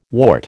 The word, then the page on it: wart: American English